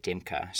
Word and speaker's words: Dymka: That's spelled D-A-M-I-A-N. His last name is D-Y-M-K-A, Dimka.